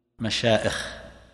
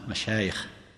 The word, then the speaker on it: مشايخ: يقولون هنا معائش غلط في اللغة، لأن الياء أصلية، من ثم فإن قراءتها بالهمز غير صحيحة، ولهذا من الخطأ أن تقول مشائخ، هذا غلط، بعض الناس هكذا يكتبها أو ينطقها مشائخ. وإذا أردت أن تضبط هذا يمكن أن تحفظ هذه العبارة القصيرة: لا تهمز المشايخ، الهمز معنوي تتكلم فيهم بالباطل، أو أن تنطق هذه اللفظة بالهمزة، لا تهمز المشايخ، لأن الياء أصلية هنا، مشايخ شيخ، ليس أصلها شأخ مثلا، حتى تقول مثلا مشايخ، واضح؟